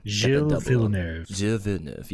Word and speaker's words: Gilles Villeneuve: So the circuit Gilles Villeneuve, I believe I'm pronouncing that correctly. – Gilles Villeneuve, yes.